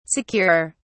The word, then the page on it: securer: British English